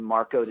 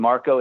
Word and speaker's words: Marko: It's my name, Marko DeSantis, and Marko is spelled M-A-R-K-O.